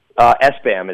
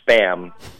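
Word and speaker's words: SBÄM: S-Bam is how it's pronounced. I know, when I first met them and did their documentary, I thought it was Spam, but like with a B instead of a P. But apparently it's pronounced S-Bam.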